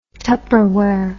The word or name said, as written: tupperware